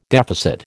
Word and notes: deficit: American English

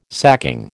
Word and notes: sacking: American English

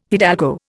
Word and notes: hidalgo: Indian

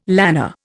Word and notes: lanner: Indian

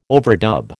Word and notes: overdub: American English

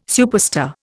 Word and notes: superstar: Indian

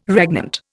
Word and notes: regnant: Indian

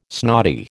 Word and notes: snotty: American English

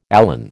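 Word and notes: élan: American English

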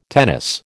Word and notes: tennis: American English

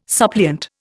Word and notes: suppliant: Indian

